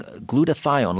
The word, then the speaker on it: glutathione: And we are talking glutathione. Let me spell that for you. G-L-U-T-A-T-H-I-O-N-E. Glutathione. G-L-U-T-A-T-H-I-O-N-E.